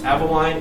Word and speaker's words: Avaline: All right, Aveline, A-V-A-L-I-N-E. – How do you say A-V-A-L-I-N-E? – What was your name?